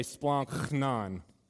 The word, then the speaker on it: splanchnon: but splanchnon, you got to put a little zombie into it if you're going to say it right. So everybody say splanchnon.